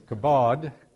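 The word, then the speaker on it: kabod (כָּבוֹד): In the Old Testament, the word kabod, notice that is not kebab, that is kabod. And actually, some English translations spell it K-A-B-O-H-D.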